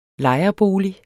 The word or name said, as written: lejerbolig